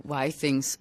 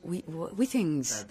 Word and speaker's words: Why Things: Nu nævner du Why Things, som jeg hører dem udtale som We Things. – Ja, det er fordi, det er fransk. – Fordi det er jo et fransk firma, så det er faktisk We Things.